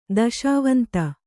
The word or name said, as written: ದೆಸೆವಂತ